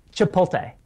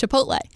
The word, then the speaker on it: Chipotle: Wait, how are you saying that? – Chipotle. Am I saying that wrong? – It's Chipotle.